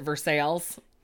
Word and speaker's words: Versailles: It's like that town in Kentucky that's called Versailles, but people pronounce it Versailles.